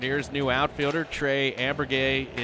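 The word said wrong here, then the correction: Amburgey: is here. Or Trey Ambergee, pardon me. Ambergee is how you pronounce it.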